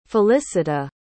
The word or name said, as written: felicita